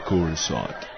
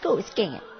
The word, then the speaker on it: Coruscant: The only question was, how do you pronounce it? Uh, dude, it's pronounced Coruscant. – No way, man, it's pronounced Coruscant.